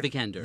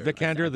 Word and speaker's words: Vikander: Vikander. – Vikander, The Danish Girl.